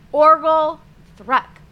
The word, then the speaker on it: Orgelthrek: You said Orgelthrek, but it's pronounced Orgelthrek with a hard G.